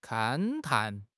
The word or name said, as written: 勘探